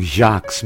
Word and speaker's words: Sjaaks: Life as a vegan is so sweet, especially when you're enjoying Jacques' many selections of vegan organic fair trade chocolates.